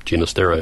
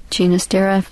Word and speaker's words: Ginastera: There is a rule about pronouncing composers' names, and that is you pronounce it usually the way they say they want to pronounce. So, I mean, when it breaks all linguistic rules you've ever heard of, that's still their prerogative. So, Ginastera it is. – Ginastera from his Catalan father.